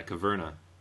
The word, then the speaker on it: Caverna: Is that how you pronounce that? Yeah, Caverna. So it's actually Agricola, but in a cave.